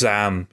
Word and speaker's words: ZA/UM: Does anyone know how to pronounce? Is this just Zaam? – I believe it's Zaam, yeah.